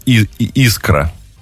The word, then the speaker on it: искра: Это как потанцуем, я все время проверяю себя, где ударение в слове «искра». Типа, я всегда вспоминаю, где снимали «Дом-2», а его снимали в «Истре».